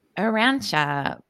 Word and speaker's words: Urantia: Cause the spelling is U-R-A-N-T-I-A. Yeah. Arantia. But yeah, they all, it's very, if you go look at it, it's definitely one of those like inspirational type of things.